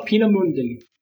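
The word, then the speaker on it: Peenemünde: So how do you say that again? – Peenemunde. Peenemunde. – Peenemunde. – Yeah. Peenemunde.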